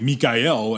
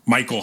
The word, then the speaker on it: Michael: I'm reminded of the year that Mikael Haneke. For Amor, I learned that it was like Mikael and not Michael Haneke.